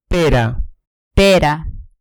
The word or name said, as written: pera